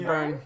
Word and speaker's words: Byrne: Did you say her name Byrne? Is that how you say her last name? – Yeah, it's B-Y-R-N-E. – I never knew that. – Yeah, I think it's Byrne.